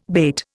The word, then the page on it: bate: Indian